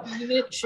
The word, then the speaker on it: Jovovich: Jovovich. – Yep. – Is that how you pronounce it? So, Jovovich, yeah.